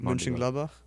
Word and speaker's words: Mönchengladbach: Mönchengladbach. I don't remember how to say it. – Mönchengladbach? – Yes.